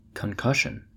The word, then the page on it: concussion: American English